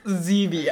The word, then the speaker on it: Zevia: Your mom goes, it's pronounced Zevia.